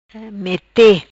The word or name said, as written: Mete